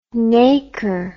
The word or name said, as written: naker